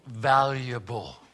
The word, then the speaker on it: valuable: 今日の英会話、ワンポイント英会話レッスンは、ちょっと発音が難しいですけども、 I'm valuable. – I'm valuable. – I'm valuable. – 私には価値があります。 – Yeah. The V sound, you こする your lower lip and say valuable.